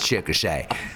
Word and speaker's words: Chickasha: That was I've had that pronunciation in my head exactly about 18 hours when the rental car guy said it's Chickasha.